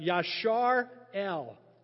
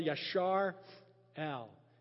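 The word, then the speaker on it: Yashar El (יִשְׂרָאֵל): And it's the pronounced Yashar El. Israel, Yashar El.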